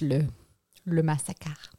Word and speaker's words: le massacre: Yeah, it's le massacre.